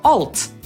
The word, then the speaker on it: Alt: Natomiast nazwę tego drugiego klawisza, który po polsku nazywamy Alt, po angielsku wymówimy raczej jako Alt, dlatego że to jest skrót od słowa alter, czyli zmieniać.